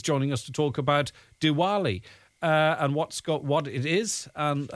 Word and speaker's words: Diwali: Diwali, if I'm pronouncing that correctly.